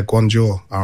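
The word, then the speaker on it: Guangzhou R&F: Is it Guangzhou RNF? – Yeah, Guangzhou RNF.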